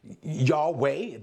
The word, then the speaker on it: Yahweh: Does not Moses know that his name is Yahweh? This is how we pronounce it, Yod-Heh-Wah-Heh.